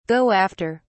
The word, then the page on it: go after: British English